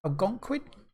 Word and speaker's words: Algonquin: Um, is that the word I was trying to say? – Yeah, Algonquin. – Algonquin. That's how pronounce it.